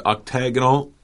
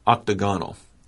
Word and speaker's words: octagonal: You said octagonal, and another pronunciation is octagonal. So I just want to let our listeners know there are two pronunciations of that.